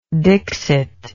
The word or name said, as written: dixit